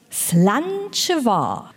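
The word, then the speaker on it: Slàinte mhath: Slanchevar. So habe ich mich zumindest informiert, dass es ausgesprochen wird. Es wird absolut anders geschrieben. – Ich glaube dir das einfach. – Slanchevar.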